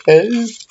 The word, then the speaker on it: coup: It's called something like catching coo. With coo spelt like, you know, like a coo. Counting coo. So it's spelled C-O-U-P.